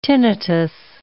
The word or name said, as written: tinnitus